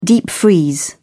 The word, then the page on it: deep freeze: British English